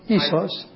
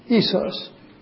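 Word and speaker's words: isos (ίσος): It's pronounced isos. Isos. Isos in Greek. I-S-O-S. From which we derive our isosceles triangle, where the centers, the sides, are equal, mathematically equal.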